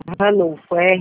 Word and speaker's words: Ranulpho: Ranuto? – Ranulfo, RANULPHO. – PHO? – Isso, Prata.